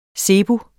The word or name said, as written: zebus